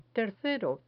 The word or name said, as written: tercero